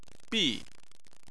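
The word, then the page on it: bi: tone 4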